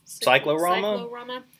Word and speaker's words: Cyclorama: There's one other YA called, how do you say that? Cyclorama? – Cyclorama.